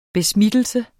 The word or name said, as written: besmittelse